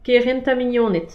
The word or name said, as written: kerent ha mignoned